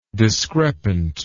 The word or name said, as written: discrepantly